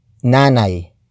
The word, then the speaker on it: nanay: In this sentence, however, we have nanay. Nanay. Nanay. Nanay is spelled as capital N-A-N-A-Y.